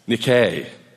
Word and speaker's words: Nike: Which was Greek for victory. It's actually pronounced Nike.